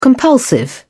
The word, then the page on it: compulsive: British English